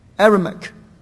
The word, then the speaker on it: Aramaic: One is in Hebrew, the other is in how do you pronounce it? Aramaic. Thank you. Aramaic.